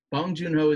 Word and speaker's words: Bong Joon-ho: Bong Joon-ho. Is that am I saying it? I said it. Yep. Bong Joon-ho is the director, the writer, the producer.